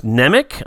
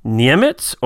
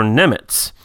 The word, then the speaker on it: Nemec: Uh, now, I already all looked at this, and I always thought it was Nemec. That's how I pronounced it. Um, but as I was reading through uh, on the uh, IMDb, it says his surname is actually pronounced Niemitz or Nemitz.